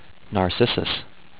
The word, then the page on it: Narcissus: nar-sis-sus